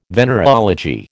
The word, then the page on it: venereology: American English